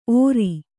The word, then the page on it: ಓರಿ: Kannada